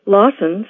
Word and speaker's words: Lawson: Lawson sounds a bit like blossom or awesome, except for the N at the end.